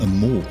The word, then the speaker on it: Meaux: Juli gibt es eine Veranstaltungsreihe zu Tolkien im Ersten Weltkrieg in Meaux in Frankreich. Ist auch richtig ausgesprochen, glaube ich, ne? – Ja.